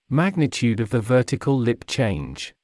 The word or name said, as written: magnitude of the vertical lip change